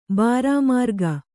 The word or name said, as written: ಬಾರಾಮಾರ್ಗ